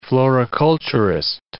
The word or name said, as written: floriculturist